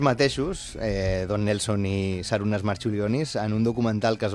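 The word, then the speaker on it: Marčiulionis: Jo crec que li diuen Marciulionis, però... Marciulionis. Perquè que aquesta C es pronuncia com una TX, natural pronunciarien com una TX.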